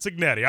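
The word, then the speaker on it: Cignetti: Signetti, is that right? Signetti? – Kurt Signetti.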